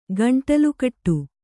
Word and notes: ಗಂಟಲುಕಟ್ಟು: gaṇṭalu kaṭṭu

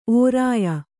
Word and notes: ಓರಾಯ: Kannada

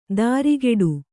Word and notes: ದಾರಿಗೆಡು: dārigeḍu

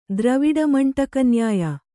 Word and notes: ದ್ರವಿಡಮಂಟಕನ್ಯಾಯ: dravaviḍa maṇṭaka nyāya

